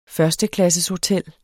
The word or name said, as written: førsteklasseshotel